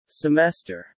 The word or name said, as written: semester